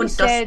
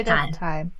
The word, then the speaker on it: Dostal: Michelle, wirst du so ausgesprochen? Michelle oder Michelle Dostal? – Michelle. – Michelle und Dostal. Dostal. – Genau. genau.